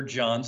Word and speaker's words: Jon: Easiest way to find me, even though I'm a 50-something-year-old man with gray hair, is on Twitter at Pastor John Self. My name is spelled J-O-N for John because H's are O.